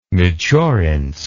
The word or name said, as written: Michurinsk